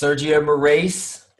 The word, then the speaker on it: Sergio Moraes: Sergio Moraes. – Yes, Sergio Moraes.